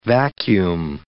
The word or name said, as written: vacuum